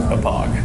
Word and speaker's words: Hauppauge: Oh, is that how you say it? Hopage? – Hopage.